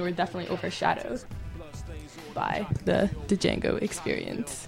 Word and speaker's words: Django: Silent D. – Django. The D is silent.